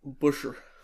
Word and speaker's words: Buescher: It's like Bush. – Buescher. – Buescher. – Buescher. – Buescher. – It just doesn't sound right. Sorry. All right. Heat race number three. But Buescher does. – Buescher. He's got B-U-E.